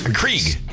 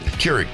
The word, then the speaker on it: Keurig: Johnny brought in a coffee maker because Krieg. – Krieg. – Keurig.